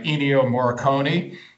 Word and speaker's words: Ennio Morricone: Ennio Morricone.